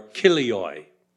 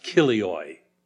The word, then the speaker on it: chilioi: The word thousand is chilioi or kilioi. It's like chilies, but C-H-I-L-I-O-I. Chilioi, pronounced kilioi. Kilioi.